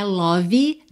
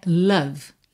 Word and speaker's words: love: Vamos aproveitar que é dia dos namorados, que nós estamos falando de amor, para caprichar na pronúncia dessa palavra, que não é love, não é love, é love, love. L-O-V-E. Bom, o E não é pronunciado. E o O não é um O caprichado, gordinho, redondinho. É E, E. Love, love. E você termina o som no V. Love.